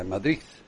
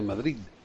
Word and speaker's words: Madrid: Yo nunca he entendido el susto de la D, porque ahí también hay gente, mucha gente en España que la D es al final, pronuncian como Z, la de Madrid, en vez de Madrid.